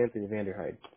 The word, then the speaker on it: Vanderhyde: It'll be Anthony It's actually Anthony Vanderhyde, so I gotcha.